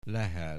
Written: Lehel

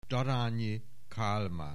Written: Daranyi Kalman

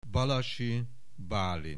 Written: Balassi Balint

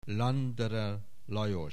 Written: Landerer Lajos

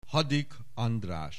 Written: Hadik, András

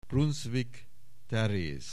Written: Brunszvik, Teréz

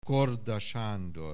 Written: Korda, Sándor

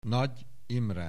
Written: Nagy Imre

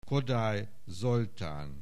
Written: Kodály, Zoltán

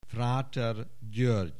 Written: Fráter György

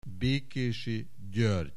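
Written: Bekesy György

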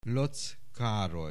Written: Lotz Karoly

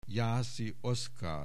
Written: Jászi Oszkár

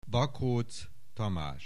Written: Bakocz Tamas

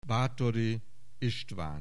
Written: Bathory Istvan

